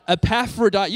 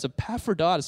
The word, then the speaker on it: Epaphroditus: Epaphroditus.